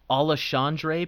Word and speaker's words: Alexandre: And then we have the two championship fights, which is Brandon Moreno, who's minus 200, who's taking on Alejandro Pantoja. Is that, I'm saying that right? – Alexandre Pantoja.